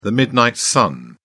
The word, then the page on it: midnight sun: British English